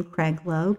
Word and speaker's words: Kreglo: It's a good old West Virginia spelling for Adrian, and it's A-D-R-A-I-N-E, last name Kreglo, K-R-E-G-L-O.